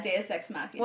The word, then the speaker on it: Deus Ex Machina: There were definitely some, like, was it Deus Ex Machina? Is that how you say it? – Deus? Yeah, Deus Ex Machina.